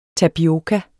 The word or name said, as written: tapioka